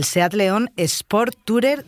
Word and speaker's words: Seat León Sportstourer: El Seat León Sport Tourer de 130 caballos.